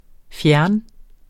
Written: fjerne

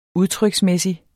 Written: udtryksmæssig

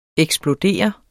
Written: eksplodere

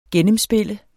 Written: gennemspille

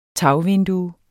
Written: tagvindue

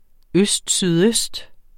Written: østsydøst

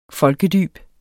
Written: folkedyb

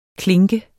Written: klinke

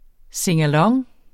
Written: singalong

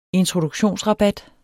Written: introduktionsrabat

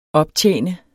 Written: optjene

